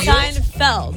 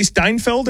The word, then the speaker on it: Seinfeld: Like no, Seinfeld. What did I say? Seinfeld. – I was like, wait, it may be spelled like that. – Friends. I'll go Friends. – You don't want to say Steinfeld or whatever you said earlier? – Steinfeld.